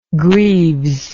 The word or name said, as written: greaves